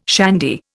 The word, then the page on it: shandy: Indian